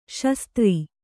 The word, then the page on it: ಸುಸ್ಥಿರ: susthira